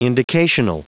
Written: indicational

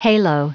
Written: halo

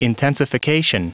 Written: intensification

